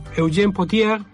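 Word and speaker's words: Eugène Pottier: La letra es de Eugène Potier.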